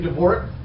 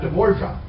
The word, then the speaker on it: Dvorak: I say, please pronounce his name Dvorak, not Dvorka.